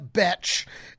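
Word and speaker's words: Bethe: How is Beth Betch and Correa Correa? And like they mispronouncing everything down there.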